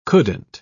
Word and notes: couldn't: British English

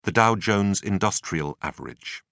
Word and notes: dow-jones industrial average: British English

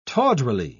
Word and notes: tawdrily: British English

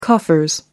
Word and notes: coffers: British English

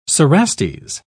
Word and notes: cerastes: British English

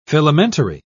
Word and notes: filamentary: British English

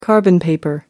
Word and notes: carbon paper: American English

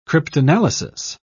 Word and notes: cryptanalysis: British English

